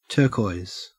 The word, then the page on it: turquoise: Australian English